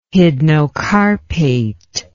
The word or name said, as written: hydnocarpate